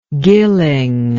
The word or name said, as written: gilling